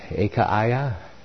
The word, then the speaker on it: Achaia: with all the saints which are in all Achaia. Or is that Achaia? Yes, the pronunciation. Achaia.